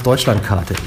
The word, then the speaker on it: Deutschlandkarte: Well, I like the way you pronounce Deutschlandkarte. That's nice. It's perfect. – The Brazilian accent. – Perfect. Yeah, perfect.